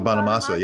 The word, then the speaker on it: Bonamassa: B Joe Bassamana. – Oh, nice. – Oh, Joe Bonamassa.